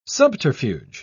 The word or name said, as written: subterfuge